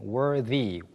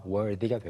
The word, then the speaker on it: worthy: 훌륭한 또는 자격이 있는을 가리키는 worthy, worthy. 그냥 y가 빠진 형태는 worth. the 발음인데 y가 붙으면서 the 발음이 되어서 worthy가 되고요. 어떤 것을 받을 자격이 있는이라고 연결하고 싶을 때는 worthy of를 같이 씁니다.